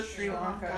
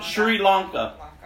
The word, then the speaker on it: Sri Lanka: Sri Lanka. – Sri Lanka.